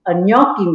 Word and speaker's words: gnocchi: Matter of fact, we just got a new machine, a gnocchi machine.